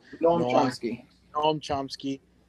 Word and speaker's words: Noam Chomsky: I saw it's a Noam Chomsky. I don't know if I'm pronouncing it right. – Noam Chomsky.